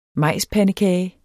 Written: majspandekage

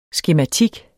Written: skematik

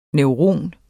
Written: NEURON

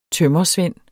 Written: tømrersvend

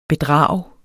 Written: bedrag

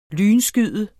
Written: lynskyde

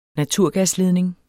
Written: naturgasledning

